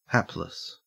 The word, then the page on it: hapless: Australian English